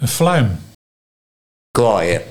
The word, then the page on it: Kwaaier: Nieuw lekkerlands